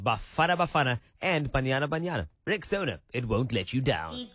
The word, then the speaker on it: Rexona: A brand you've trusted for years is stepping forward with a bold new name, and it's pronounced Rexona.